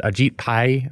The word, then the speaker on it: Ajit Pai: Is it Ajit Pai? Is that how it's pronounced? Uh, it's close. Yep. Yep. Yeah, pretty close.